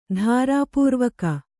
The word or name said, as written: ಧಾರಾಪೂರ್ವಕ